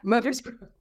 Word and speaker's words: Murfreesboro: And I'm going to really mispronounce where you come from. Murfreesboro? – Is that Murfreesboro? – Right? Yeah. – Murfreesboro. Thank you.